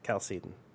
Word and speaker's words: Chalcedon: I mean, is that how you pronounce it? I've never heard anyone say it. That's the thing. I've only read it. – Chalcedon. – Okay. – That's how I've always said it.